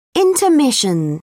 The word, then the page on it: intermission: British English